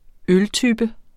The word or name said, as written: øltype